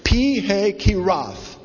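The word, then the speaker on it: Pi-hahiroth: It's actually pronounced Pihahiroth. Pihahiroth.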